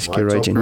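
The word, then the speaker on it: Gearóidín: Yeah, it's spelled G-E-A-R-O-I-D-I-N. – Okay. – Sheesh. Geroiden? – almost. Geroy Jean.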